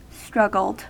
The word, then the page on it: struggled: American English